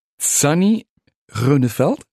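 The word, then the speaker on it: Sunnie Groeneveld: Sani Rönnefeld? – Ja, das ist die holländische Aussprache. Das ist richtig, meinen Vorfahren entsprechend.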